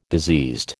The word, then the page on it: diseased: American English